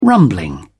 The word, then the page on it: rumbling: British English